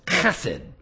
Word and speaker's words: chesed: but the old ancient Hebrew word is chesed, chesed, C-H-E-S-E-D. When you say it right, something lands on the back of the neck of the person in front of you. Chesed. It's a guttural, chesed.